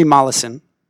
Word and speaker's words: Mollison: How do you pronounce his last name again? – Henry Mollison. – Mollison. Okay, it looks like Mollison's. Yeah. Henry Mollison.